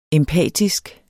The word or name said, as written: empatisk